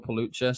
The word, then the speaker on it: Pelucha: Phil Pelucha, surname is spelled P E L U C H A.